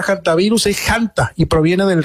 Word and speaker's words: hantavirus: Creo que se refiere a la palabra hantavirus, y la palabra hantavirus no es una palabra en castellano, por eso se pronuncia como se pronuncia. Es una palabra que apareció en Corea hace bastante tiempo. Créame que me he tomado la tarea de buscar. La pronunciación se podría pronunciar también con la H muda, por supuesto, y también es válida la pronunciación haciendo el énfasis en la primera sílaba con la letra J de Janta, aunque también es válida la forma antivirus.